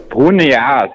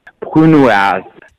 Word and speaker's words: Brunoise: Oder wie spricht man das jetzt aus? Brunias. Brunias? Oas. Nur mal zum Mitschreiben? Brunoise. Dann sag an, was ist denn Brunoise?